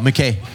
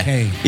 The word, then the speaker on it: MacKaye: Oh, MacKaye. Yeah. MacKaye.